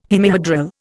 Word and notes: hemihedral: Indian

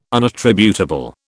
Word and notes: unattributable: British English

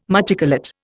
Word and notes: machicolate: Indian

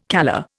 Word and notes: calla: Indian

